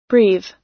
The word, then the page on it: breathe: American English